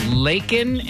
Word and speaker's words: Laykin: Laykin in Carrollton. – Yes. – Is that how you say your name? – It is. – Rhymes with bacon? – I get that a lot, unfortunately.